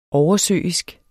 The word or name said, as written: oversøisk